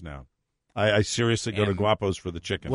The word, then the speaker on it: Guapos: Guapos. – Guapos. – Have you been by a Guapos? – Spell it. – Oh, I thought you said Wapos. – No. – Was it an Italian Guapos. G-U-A-P-O-S.